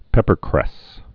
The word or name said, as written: peppercresses